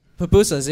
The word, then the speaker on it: pupusas: Pupusas.